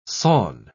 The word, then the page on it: sawn: British English